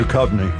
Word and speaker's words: Duchovny: And on Jared's Culture Show, starting at 2, it's a best-of episode featuring David Duchovny, I think I said that right. – Duchovny. – Duchovny. Thank you.